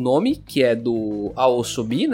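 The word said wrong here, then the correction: YOASOBI: né? Yaosobi, assim que pronuncia, Yaosobi.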